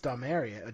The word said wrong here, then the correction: Dominaria: Uh, Dom, I can never pronounce it. Domaria. Dominaria. Dominaria. Dominaria. Yeah. There's, there we go.